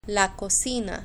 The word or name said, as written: la cocina